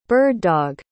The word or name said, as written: bird-dog